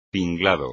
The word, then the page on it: tinglado: espagnol